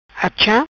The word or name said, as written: Achâ